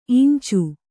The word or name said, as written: ಇಂಚೆ